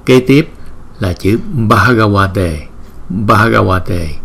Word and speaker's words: Bhagavate: Chữ B và chữ H chúng ta sẽ đọc là B, B. Nếu mà chữ B không thì chúng ta đọc là B. Nhưng chữ B đính kèm với chữ H thì chúng ta đọc là B. Cái hơi trong miệng chúng ta đưa ra nhiều một chút. B, B. Bhagavate, Bhagavate, Bhagavate.